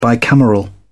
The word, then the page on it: bicameral: British English